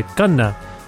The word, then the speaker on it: Khanna: That's like the Indian pronunciation. You'd say Khanna. But I feel like it's too hard for some people to even say Khanna or Vish, as you may have heard on the show.